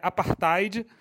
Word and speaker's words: apartheid: A pronúncia correta é apartheid,